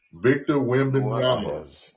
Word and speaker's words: Wembanyama: How you say it? – Victor Wembanyama.